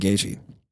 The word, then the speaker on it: Gaethje: Oh, I forgot to say my other fighter that I really like to watch after watching is Justin Gaethje. Is that saying his name right? – Yeah, he's in the same camp as same team as Usman and Rose.